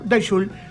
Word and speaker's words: Die Schule: El lugar se llama Daishul. Die Schule se escribe, pero Daishul es la pronunciación correcta y era una especie de apéndice del legendario Cemento.